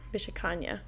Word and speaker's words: Vishakanya: V-I-S-H-A space Visha. K-I-N-Y-A. – Kanya. Vishakanya.